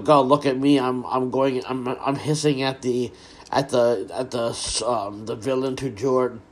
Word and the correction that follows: du jour: Du jour, sorry.